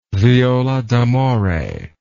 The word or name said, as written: viola d'amore